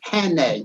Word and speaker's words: Jene: And Jean, well, it actually is pronounced it Henne, because I spell my name J-E-N-E, and it's like Amazon.